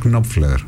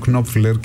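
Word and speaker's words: Knopfler: Όταν ακούς λες, αυτό είναι Μαρκ Νόπφλερ. Ναι. Κάποιοι τον προφέρουν Κνόπφλερ και με συγχωρείτε, μου έχουν πει ότι από τον τόπο που κατάγεται αυτή είναι η σωστή προφορά.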